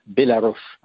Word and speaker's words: Bélarus: L'entrée principale est toujours Biélorussie et ils disent en biélorusse Bélarus, hein, parce que c'est comme ça que ça se prononce.